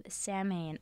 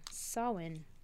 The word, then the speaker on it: Samhain: Samhain. – Samhain. – I'm so Samhain. – I'm so Samhain. – Okay. Back at it. Focus. It was believed that phantoms walked the earth on the night of Samhain, so people would dress up and go...